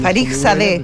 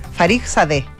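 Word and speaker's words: Fakhrizadeh: Farizadeh. Se pronuncia, me dijeron los expertos en iraní de esta radio. – Farizadeh. Y la K que hay antes, F-A-K-H, bueno, es que se es muda, es como la H en iraní.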